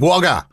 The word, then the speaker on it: Wagga: Is that how you say it? Is it? You've got to go up towards the end. Wagga!